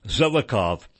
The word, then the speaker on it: Zelikow: Zelikow, or Zelikow, Z-E-L-I-K-O-W, I'm pronouncing it Zelikow, who was the executive director of the commission that investigated September 11.